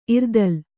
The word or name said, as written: ಇರ್ತಲೆ